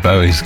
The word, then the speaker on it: Bowie: David Bowie's golden years. So we had a memo about David Bowie.